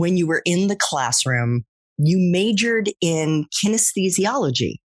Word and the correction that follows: kinesiology: Is that right? – Kinesiology. Yeah. – How do you pronounce it? – Kinesiology.